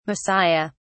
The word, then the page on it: Messiah: British English